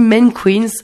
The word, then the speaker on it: MNNQNS: ça s'écrit MNNQNS et ça se dit Men Queens et donc Born Idiot, et ça, ce sera dans le club.